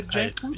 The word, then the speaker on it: Jankowicz: Nina Jankowicz, is that how you say her name? Nina Jankowicz? – Yes, I think so.